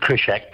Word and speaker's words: Kryshak: Well, I pronounce it Kryshak, but you can pronounce it however you like.